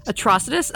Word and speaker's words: Atrocitus: I'm going to go Atrocious. I can't even say it properly, which does not bode well for me. Atrocitus.